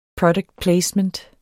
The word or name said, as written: product placement